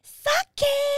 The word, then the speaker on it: sake: And today's podcast is Sake. – There she goes. She said it right. Sake.